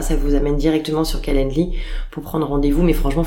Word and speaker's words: Calendly: sur le site Calendly, je le prononce bien. Ouais, c'est ça, Calendly, exactement. – Comment, comment tu l'écris Alors, C-A-L-E-N-D-L-Y.